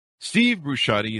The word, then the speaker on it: Steve Brusatte: Steve Brusatte is on the show with us today, and he's going to be talking to us about his newest book.